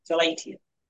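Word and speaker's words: philautia: There was also philatua. I'm pronouncing it wrong. Philatia.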